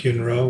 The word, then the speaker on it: Yun Rou: I'm on Facebook as Monk Yun Ro, Y-U-N, new word, R-O-W, R-O-U, Y-U-N.